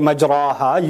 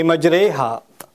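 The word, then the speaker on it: مجريها: You don't say majraha, you say majreha.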